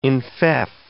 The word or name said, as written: enfeoff